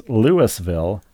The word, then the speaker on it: Louisville: a mere 10 miles away from Louisville, pronounced Louisville, Georgia.